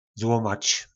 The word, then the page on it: zlomac: po našimu